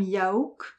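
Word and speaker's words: Yauch: Et donc il est composé de trois personnes, MCA, donc Adam Yaouk, ça se prononce, Mike D, Michael Diamond, et Ad Rock, Adam Horowitz.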